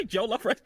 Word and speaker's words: jollof: Did I say it wrong? – It's just jollof rice. Jollof rice, yeah. – Jollof rice? Jollof. – You're like jollof rice. It's jollof rice.